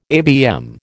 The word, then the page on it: ABM: American English